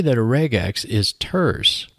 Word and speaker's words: regex: Some people might pronounce this as regex. I keep the hard G from regular and say regex.